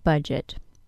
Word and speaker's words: budget: True, we don't hear a D sound in the word budget. We only hear a J sound.